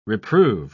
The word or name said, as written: reprove